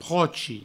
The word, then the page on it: prochi: provençal